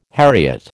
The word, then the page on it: heriot: American English